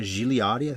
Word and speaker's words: Gigliotti: Peter Chernin, Donna Gigliotti, I think is how you say her name.